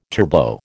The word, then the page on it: turbo: American English